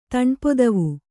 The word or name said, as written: ತಣ್ಪೊದವು